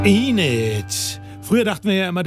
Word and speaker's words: Enid: Da haben wir immer die Bücher von Enid Blyton gekannt, aber es ist natürlich Enid, so wird der Name ausgesprochen.